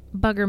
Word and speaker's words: Bugger: It's called the Phantom Trucker of Bugger, but it's spelled like booger. – Bugger Mountain. – Okay, Bugger Mountain. Okay, Bugger Mountain. I'm sure all the kids there call it Booger Mountain. – Well, it's sure the way it's spelled.